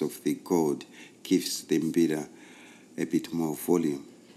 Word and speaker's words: mbira: Mbira is spelled M-B-I-R-A, mbira. And we take off the M, B-I-R-A, it becomes that's the name of the ceremony.